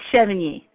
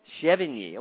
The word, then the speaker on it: Chevigny: Why don't Chevigny. You tell us? – It's Chevigny. – Chevigny. Well, I was close.